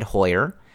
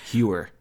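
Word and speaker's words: Heuer: It is spelled H-E-U-E-R. I believe on Marquee, Boog has said Hoyer. So that's what I'm going with. If I am butchering that, I apologize. So Hewer. – Yeah, I don't think it's Hewer, but we're going with Hoyer.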